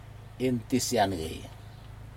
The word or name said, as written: intisjage